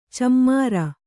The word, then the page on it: ಛೀಮಾರಿ: chīmāri